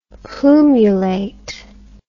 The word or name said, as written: plumulate